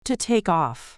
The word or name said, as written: to take off